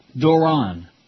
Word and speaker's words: Doron: Doron. Rhymes with moron.